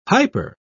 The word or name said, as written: hyper